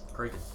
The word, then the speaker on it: Kraken: So what does it actually pronounce as? – Kraken. – Kraken, got it.